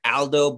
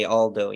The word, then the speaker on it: Aldo: Technically it was supposed to be pronounced in Italian, so it's, like, supposed to be with an open A, like Aldo, but it's like, you know, how are you going to tell an English speaker not to say Aldo, you know, it's not... It's not wrong, you're just pronouncing it in a different language, you know, it's...